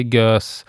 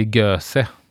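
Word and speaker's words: Gose: Vissa säger GÖS, andra säger GÖSE jag vet inte riktigt vad man säger, men jag brukar nog säga gös eh, personligen.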